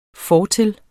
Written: fortil

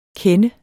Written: kender

